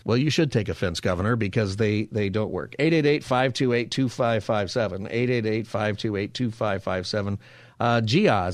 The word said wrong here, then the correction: Jaya: is that, am I saying your name right in Los Angeles? Jia? – Jaya. – Jaya. Sorry, Jaya.